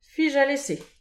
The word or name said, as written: fich alese